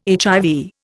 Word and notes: HIV: Indian

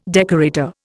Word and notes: decorator: Indian

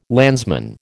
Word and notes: landsman: American English

